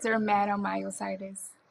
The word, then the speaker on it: dermatomyositis: correct me if I'm saying it wrong, dermatomyolitis, right? That's it's bad. I'm not a nurse and I don't know how to say it. That is horrible. – It's okay. It took me a while too. Okay, girl. It's called dermatomyositis. – Okay, yeah. That. I was way off.